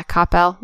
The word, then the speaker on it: Coppell: Coppell. – Is it Coppell? I'm not. Yeah, Coppell.